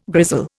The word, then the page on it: grizzle: Indian